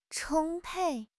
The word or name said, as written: 充沛